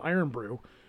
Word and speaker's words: Irn-Bru: So, of course, I had to go to Jungle Gems, and I had to get my case of Iron Brew, which is spelled I-R-N-B-R-U.